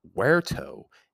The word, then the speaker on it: verto: Another one that we get is verto. It's actually spelt V-E-R-T-O. But pronounced where, because V's in Latin are pronounced as W's.